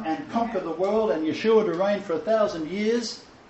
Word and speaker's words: Yeshua: And his name was Yeshua. There was no pronounced A at the end of his name in the Galilean extract.